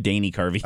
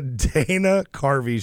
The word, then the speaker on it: Dana Carvey: Did I say that wrong? – You said Danny Carvey. – Oh, sorry. It's the cold. – Yeah, let's just blame it on the suit. – The Dana Carvey Show, which should have been this great show, came out in 1996.